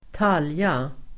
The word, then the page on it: talja: [²t'al:ja]